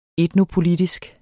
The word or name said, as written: etnopolitisk